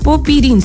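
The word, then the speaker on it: Popyrin: Clave 4, y nos vamos al tenis, porque don Alejandro Tavilo protagonizó una gran remontada ante el australiano Alexei Popirín. ¿Lo dije bien o no? Popirín, sí. Y avanzó por primera vez en su carrera a la tercera ronda del Grand Slam de Nueva York.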